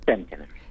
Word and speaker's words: Centenary: Centenary.